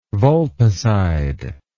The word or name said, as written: vulpicide